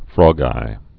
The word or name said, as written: frogeyes